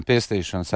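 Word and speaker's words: Pestīšanas: Тут, да, где написано Пестишинас армия, простите, я всегда до сих пор на латышском плохо ставлю ударение. – Нет, все правильно, Пестишинас армия.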